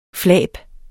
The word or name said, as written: flab